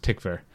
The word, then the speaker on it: Tykwer: We learned from our friend Matthias that his name is pronounced Tykwer.